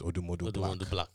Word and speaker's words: Odumodublvck: His name is Odumodu Black. – Yeah. – But it's spelled instead of BLACK, it's BLVCK.